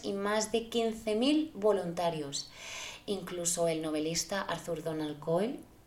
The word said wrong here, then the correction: Arthur Conan Doyle: Siempre lo digo mal, ostras, Arthur Conan Doyle, siempre, no tengo, tengo un problema con este nombre, ¿eh?